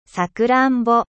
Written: cerise

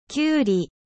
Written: concombre

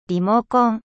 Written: リモコン

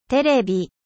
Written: テレビ

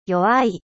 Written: faible

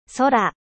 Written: ciel

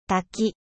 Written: 滝

